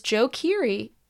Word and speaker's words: Joe Keery: Also, one thing we wanted to talk about this episode was Joe Keery.